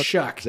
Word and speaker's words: Shough: Sorry, Shuck. Yes, it's like it's pronounced like S-H-U-C-K, even though it's spelled S-H-O-U-G-H, which makes no sense.